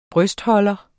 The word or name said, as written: brystholder